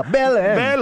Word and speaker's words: Belém: Belém.